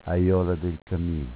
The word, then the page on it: Aiola: Dialetto